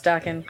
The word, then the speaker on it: Dokken: It's Dokken. It's pronounced Dokken.